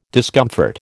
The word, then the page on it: discomfort: American English